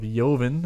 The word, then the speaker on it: Joven: We purchased a bottle of Joven. It's actually a soft J.